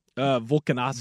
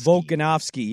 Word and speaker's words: Volkanovski: Alexander, I always say his name wrong. – Volkanovski. – Volkanovski, yeah.